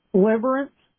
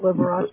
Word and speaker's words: Liberace: Liberace? – Liberace.